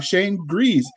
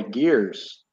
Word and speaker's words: Gries: And I probably butchered that, didn't I? – No, you got it right. – Wow. Okay. Put that in your calendar. – I know. My drill sergeant at basic training called me Gears, which that's cool.